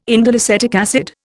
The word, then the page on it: indoleacetic acid: Indian